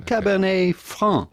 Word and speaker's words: Cabernet Franc: Cabernet Franc, Cabernet Franc, but the T at the end of Cabernet is still silent, Cabernet Franc. But for the typical French pronunciation, you do not want to pronounce the C at the end of Franc because it is silent in French, as many letters, last letters of words are just like the T of Cabernet.